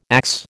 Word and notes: axe: American English

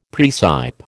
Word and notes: praecipe: American English

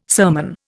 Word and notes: sermon: Indian

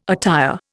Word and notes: attire: Indian

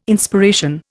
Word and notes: Inspiration: Indian

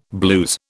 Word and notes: blues: British English

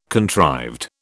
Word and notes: contrived: British English

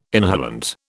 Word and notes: inhalant: British English

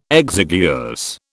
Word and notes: exiguous: British English

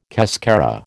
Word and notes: cascara: American English

